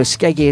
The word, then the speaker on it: Skeggae: So Skeggy is spelt like reggae. S-K-E-G-G-A-E.